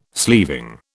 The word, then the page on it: sleeving: British English